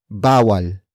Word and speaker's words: bawal: The first word is bawal. Bawal. Bawal. Bawal is spelled as B A W A L.